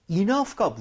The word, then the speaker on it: enough: 희한하게도 여기서 GH가 F와 비슷한 발음입니다. 그래서 enough. 바람이 나오게 돼 있어요.